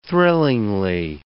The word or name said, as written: thrillingly